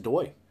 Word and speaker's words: Doi: D-O-I. – I just believe that's Doy. – Bill Doy, who was 66, and his wife, Lillian Doy, who was... Also, she was 56, he was 66. Sorry. Surprising Doy in his bedroom, Ramirez shot him in the face with a .22 semi-automatic pistol.